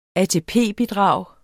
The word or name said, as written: ATP-bidrag